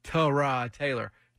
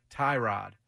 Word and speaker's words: Tyrod Taylor: The proper pronunciation for Tyrod Taylor is Tyrod Taylor, not Tyrod.